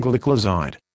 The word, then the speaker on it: gliclazide: Modified release tablets containing gliclazide, pronounced gliclazide.